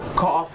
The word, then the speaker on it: cough: ออกเสียงว่า cough cough